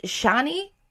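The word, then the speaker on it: Chani: He links up with Shani, is how you pronounce it, played by Zendaya, yeah.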